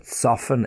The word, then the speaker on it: soften: But soften, S-O-F-T-E-N, always S-O-F-T-E-N, soften, to soften the fabric.